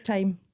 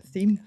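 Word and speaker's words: thyme: Time. Like the time. Herb. – The reason I asked you that is because I always struggle with how to pronounce that. – Like theme.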